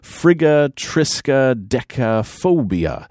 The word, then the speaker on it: friggatriskaidekaphobia: Friggatriskaidekaphobia.